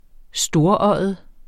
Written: storøjet

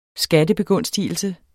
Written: skattebegunstigelse

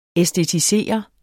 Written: æstetisere

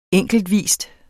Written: enkeltvis

